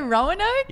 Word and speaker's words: Roanoke: Roanoke? – Roanoke. – Roanoke, Virginia. – Yeah. – I don't think that's Roanoke? – Right. – Roanoke? That's how you say Roanoke? – Yeah.